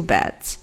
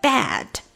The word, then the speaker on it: bed: I'd like to have a room with two beds. 那大家在说这个 bed 的时候一定要注意，因为我听到了很多人都把这个音会说错，他们 either 说成 bad 变成了 b a d，或者 bide 变成了 b i d，所以它是一个 bed bed，这个 a 是一个 short e sound，就是你的嘴巴呢，嗯、呃，呈现一个很自然放松的张开状态，哎哎哎，bed。Okay, I'd like to have a room with two beds.